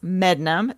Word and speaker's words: Medmenham: It's spelled Medmenham, but it's pronounced Mednum. Don't ask me why.